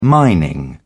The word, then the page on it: mining: British English